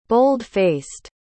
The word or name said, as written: bold-faced